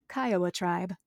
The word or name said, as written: Kiowa